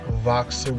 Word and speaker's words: Voxer: We use the, I'll advertise this, we use the app Voxer with a V. It's spelled like boxer, but instead of a B, there's the letter V, like vixen, voice, you know, those things.